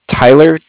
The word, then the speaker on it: Tyler: Yeah, my name's Tyler, T-Y-L-E-R. – Uh-huh. – And I'm calling with Cardiff. It's spelled C-A-R-D-I-F-F, as in Frank Frank.